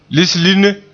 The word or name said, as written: lislinne